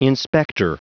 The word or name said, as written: inspector